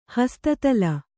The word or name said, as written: ಹಸ್ತತಲ